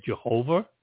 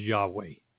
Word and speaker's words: Yahweh: Some people say Jehovah. These letters represent Jehovah. But the proper pronunciation is Yahweh.